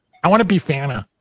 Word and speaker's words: bifana: For anyone who has never had a Bifana and is pronounced Bifana. – It's Bifana. Yeah.